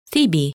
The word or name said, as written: Thebe